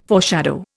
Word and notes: foreshadow: Indian

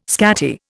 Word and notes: scatty: Indian

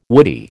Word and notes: woody: American English

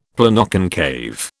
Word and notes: Planoconcave: British English

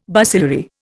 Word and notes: bacillary: Indian

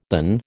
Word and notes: than: American English

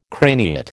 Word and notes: craniate: American English

